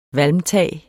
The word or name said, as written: valmtag